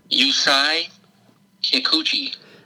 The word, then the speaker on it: Yusei Kikuchi: Yusei Kikuchi.